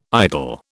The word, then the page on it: idol: British English